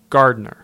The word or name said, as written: Gardener